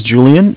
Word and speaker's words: Julian: My name is Julian. It's J-U-L-I-A-N. And I'm calling with Bank of Cardiff. It's Cardiff is spelled C-A-R, D as in David, I-F-F as in Frank Frank.